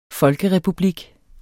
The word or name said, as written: folkerepublik